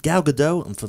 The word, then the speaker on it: Gal Gadot: Cat, Gal Gadot, Gal Gadot.